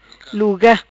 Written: Luga

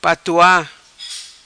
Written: Patoa

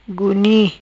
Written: Guni'

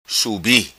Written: Subi

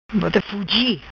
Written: Bota Fugi

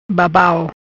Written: Babau

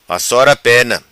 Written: vassora pena

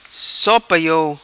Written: So Pa Iou

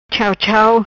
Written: Chau Chau

